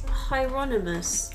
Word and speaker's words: Hieronymus: I think it's Hieronymus. – Hieronymus. – Because Hiroshima is the... – Yeah, I was just pronouncing it wrong.